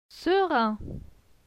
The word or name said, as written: serein